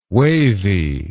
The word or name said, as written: wavey